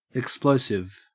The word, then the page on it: explosive: Australian English